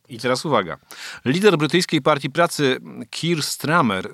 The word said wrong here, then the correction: Starmer: y, chyba tak to się wymawia, Starmer, przepraszam,